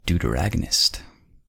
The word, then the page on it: deuteragonist: American English